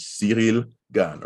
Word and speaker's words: Ciryl Gane: versus Ciryl Gane, which is how you'd pronounce his name in French. Ciryl Gane, right? – Ciryl Gane.